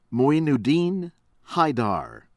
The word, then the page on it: Moinuddin Haider: American English